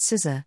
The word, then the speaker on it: sciCSR: We present sciCSR, pronounced scissor,